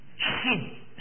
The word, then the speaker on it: Shin: Shin. That's the very way you pronounce it.